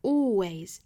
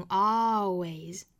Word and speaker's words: always: 那 A-L-W-A-Y-S 这个单词呢，英音是读成 always，注意元唇，美音呢读成 always, always。